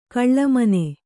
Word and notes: ಘುಳುಮ್ಮನೆ: ghuḷummane

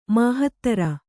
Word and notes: ಮಹೀಧ್ರ: mahīdhra

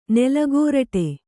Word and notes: ನೆಲಗೋರಟೆ: Kannada

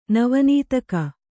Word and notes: ನವನೀತಕ: Kannada

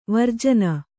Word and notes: ವರ್ಜನೆ: varjane

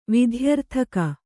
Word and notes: ವಿಧ್ಯರ್ಥಕ: kannada

